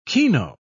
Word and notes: kino: British English

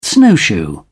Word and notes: snowshoe: English; British English

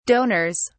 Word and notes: donors: British English

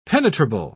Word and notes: penetrable: British English

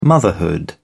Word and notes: motherhood: British English

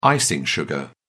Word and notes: icing sugar: British English

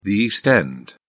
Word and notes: east end: British English

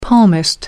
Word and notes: palmist: American English

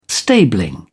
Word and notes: stabling: British English

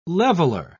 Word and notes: leveler: British English

